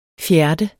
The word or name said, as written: FJERT